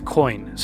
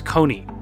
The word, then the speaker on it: Kony: You may have heard of the LRA and its notorious leader, Joseph Kony, sometimes pronounced Kony, and he's still at large.